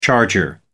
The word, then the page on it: charger: American English